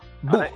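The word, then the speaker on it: Wug: Mira, la marca es Bug. – Bug.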